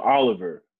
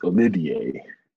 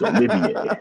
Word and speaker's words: Olivier: So I called him Oliver last time I talked about UK, but his name is pronounced Olivier. French. It's Olivier.